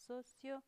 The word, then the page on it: socio: 'sosio